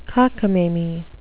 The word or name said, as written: cockamamie